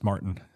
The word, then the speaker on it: Maurten: The company that makes the supplement is Martin.